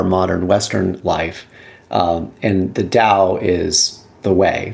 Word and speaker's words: Tao: So Taoism, the first thing I want to say is, like, sometimes you'll see the word Tao spelled with a T, sometimes with a D. It's when it's translated into English, it has different transliterations, but it's typically pronounced like with a D sound.